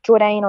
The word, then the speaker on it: Jordane: You can find me at _Jaw_Dane, because I'm trying to teach people how to pronounce my name a bit more. But yes, you can Jordane on all the Spotify and all the socials.